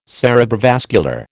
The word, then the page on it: cerebrovascular: American English